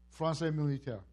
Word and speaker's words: Français militaire: What's the right pronunciation? Français militaire.